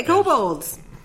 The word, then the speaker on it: kobolds: Okay, kobolds. Kobolds. They are... – I've always said T at the end of it, and I didn't realize till now that it's spelled with a D at the end.